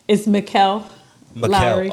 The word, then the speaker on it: Mikkel Lowry: And Michelle Lowry mean to your program? – It's Mikkel Lowry. – Oh, that looks like Michelle.